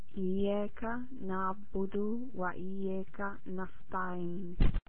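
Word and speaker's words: Iyyaka na'budu (إياك نعبد): We have two letters in Arabic language, ka and qa. So when you pronounce ka, try to make it ka. Iyyaka na'budu.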